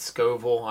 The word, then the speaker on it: Scoville: So we have 8 hot sauces, 7 of which we have the I pronounce it Scoville. I don't know if that's correct or not. – That's right.